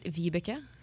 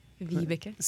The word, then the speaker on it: Vibeke: I say it's Rebecca with a V. – Now, in Norwegian, in your native language, would it be pronounced any differently? – A little bit Vibeke. – Vibeke. – Vibeke.